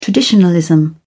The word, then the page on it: traditionalism: British English